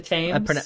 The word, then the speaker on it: Thames: It's the Thames. – It's the Thames. – So River Thames. That's how they pronounce it.